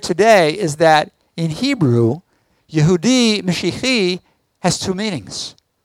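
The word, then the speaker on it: Yehudi Mishihi (יהודי משיחי): Yehudi Mishihi. I got the accent wrong. Yehudi Mishihi.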